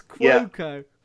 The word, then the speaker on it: Cuoco: This is the first platform for Kaylee Cuoco since The Big Bang Theory. – I think we've pronounced the name differently. It's Cuoco.